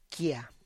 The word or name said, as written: kia